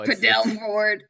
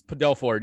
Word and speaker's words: Padelford: Padelford. Wrong emphasis on the wrong syllable. – Yeah. No, it was pointed out that it's Padelford, yeah.